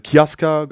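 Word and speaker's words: Casca: Äh, und auch Kaska wird halt im Japanischen teilweise Kiaska ausgesprochen.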